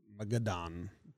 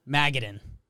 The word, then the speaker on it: Magadan: Say it quick and fun, like it's a nut. – Magadan. – Close. Magadan.